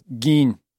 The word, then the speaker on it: Gein: Is that how you pronounce it? Gein? – I thought it was a Gein. – How's it spelled? – G-E-I-N. – G as in guy. Long E as in bead. – So it is. – Gein. – Gein.